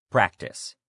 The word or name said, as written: practice